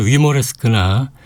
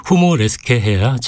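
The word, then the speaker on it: Humoreske: 그렇기 때문에 위모레스크나 휴모레스크 보다는 독일어 발음도 어렵지 않습니다. 후모레스케 해야 작품의 이해가 쉽습니다. 로베르트 슈만의 후모레스케 작품 번호 20, B플랫 장조, 이모젠 쿠퍼의 피아노 연주입니다.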